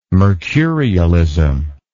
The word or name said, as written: mercurialism